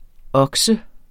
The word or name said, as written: okse